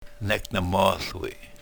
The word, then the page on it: nek ne-mohl wi: yurok